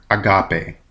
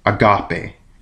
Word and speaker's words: agape: Charity in verse 4 is the Greek word agape. That's agape. A-G-A-P, long E.